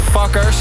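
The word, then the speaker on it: Fcukers: Het wordt alweer geremixed door het allerhipste duo uit New York, Fuckers. Wat ze een beetje verkeerd schrijven, maar zo spreek je het wel uit.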